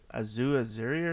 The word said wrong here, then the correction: Aziz Ansari: or something? – Oh, Aziz Ansari? – Yeah. I fucking totally butchered his name.